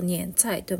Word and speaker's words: 年菜: で、それを、えっ、ー、と、二円菜と言いますね。二円菜は、二円は 1 年、 2 年、 3 年の年ですね。で、えっ、ー、と、菜は野菜の菜です。年、年菜って書いて、えっ、ー、と、二円菜と読むんですけど、